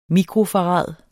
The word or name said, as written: mikrofarad